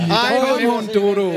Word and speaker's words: Dodo: Det var en sjov historie, da du besøgte os sidst, Dodo. – Ja. – Og hvor vi lærte, at det er Dodo. – Ja, det er faktisk Dodo. – Og det er faktisk uden den engelske udtale.